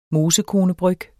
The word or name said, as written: mosekone